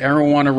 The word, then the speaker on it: Arowana: Am I the only one who noticed that Arowana rhymes with marijuana?